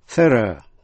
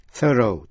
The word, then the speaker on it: thorough: Nhớ là R-O-U-G-H trong thorough đọc là thorough theo giọng Anh, thorough theo giọng Mỹ.